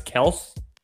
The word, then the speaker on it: Kelce: the Kansas City tight end, Travis Kelce, said this whole time his name's been pronounced wrong, and it's actually Travis Kelce.